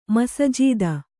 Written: ಮಸಜೀದ

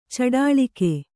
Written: ಚಡಾಳಿಕೆ

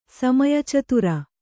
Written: ಸಮಯಚತುರ

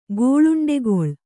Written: ಗೋಳುಂಡೆಗೊಳ್

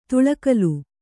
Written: ತುಳಕಲು

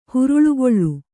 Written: ಹುರುಳುಗೊಳ್ಳು

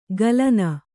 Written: ಗಲನ

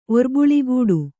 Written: ಒರ್ಬುಳಿಗೂಡು

